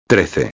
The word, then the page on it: 13: espanhol